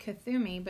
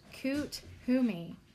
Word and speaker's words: Kuthumi: I always said Kathumi, but it says pronounced Kuthumi.